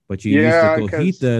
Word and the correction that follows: cotija: and the chili relleno. – Yeah, that's right. – Hell yeah. I said it right. – Cotija, almost. – Oh, cotija. – Cotija, yeah. – Okay, cotija, that's what it's called. – Yep, you're saying it right.